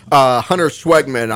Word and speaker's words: Huntyr Schwegman: My guest today is a former Minecraft streamer, and he's also the sports editor of the Chanute Tribune, Huntyr Schwegman.